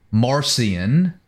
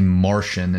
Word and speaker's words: Marcion: It wasn't until probably Marcion, as we pronounce it, it's actually Martian is the correct pronunciation,